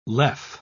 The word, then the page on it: lev: British English